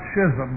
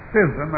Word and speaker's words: schism: It's not schism, by the way. It's schism. It rhymes with scissors.